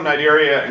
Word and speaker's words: Cnidaria: The phylum Cnidaria, and notice that the C is silent when you say the word. The phylum Cnidaria include organisms such as your coral, your jellyfish, and your Portuguese man-o-war.